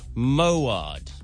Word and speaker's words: Moawad: Trevor Moad. It's spelled M-O-A-W-A-D.